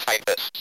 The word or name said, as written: typists